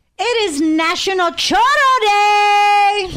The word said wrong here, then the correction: churro: No, chorro is diarrhea in Spanish. It is not chorro. – That day. It is churro, churro.